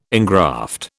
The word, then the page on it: engraft: British English